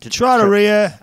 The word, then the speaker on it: trattoria: Trattoria? Is that how you pronounce Italian restaurant? – Trattoria.